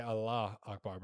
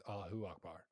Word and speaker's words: Allahu Akbar: A lot of people say Allah Akbar, but the actual pronunciation is Allahu Akbar.